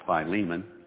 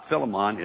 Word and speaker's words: Philemon: Some people call it Philemon. I've heard it pronounced both ways. Philemon is right before Hebrews.